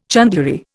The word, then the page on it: chandlery: Indian